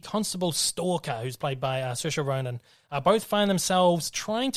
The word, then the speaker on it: Saoirse: I can never work out how to pronounce her name. – Saoirse. Saoirse Ronan.